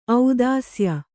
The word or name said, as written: ಔದಾಸ್ಯ